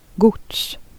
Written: transport

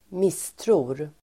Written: misstror

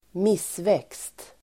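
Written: missväxt

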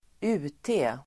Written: UT

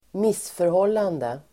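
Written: missförhållande

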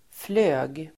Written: flög

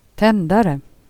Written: cigarrett

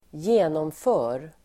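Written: genomför

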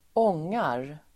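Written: ångar

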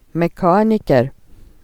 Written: flyg

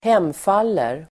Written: hemfaller

